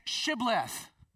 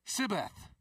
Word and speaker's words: Shibboleth: Because apparently the Ephraimites had a unique dialect that meant that they couldn't pronounce the sh, the H. Hey, say Shibboleth, Sibboleth. Oh, I know who you are. And they would take him because of the mispronunciation, the accent basically employed.